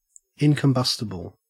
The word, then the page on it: incombustible: Australian English